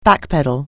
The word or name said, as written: backpedal